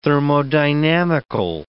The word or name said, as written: thermodynamical